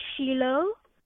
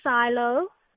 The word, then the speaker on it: Shiloh: S-H-I-L-O-H. – Okay, so, how do people pronounce it? – They say Shiloh, or they'll say Silo. – Oh, of course. – But it is? – Yeah, but it's Shiloh.